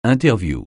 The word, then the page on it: interview: francais